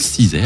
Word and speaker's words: SZA: Et c'est un album de Cizay. Alors ça s'écrit S-Z-A, mais ça se prononce Cizay apparemment.